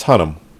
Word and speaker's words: Hunnam: Paul Hunnam. I forget how they pronounce it. – It's Hunnam.